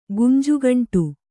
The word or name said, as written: ಗುಂಜುಗಂಟು